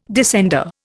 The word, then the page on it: descender: Indian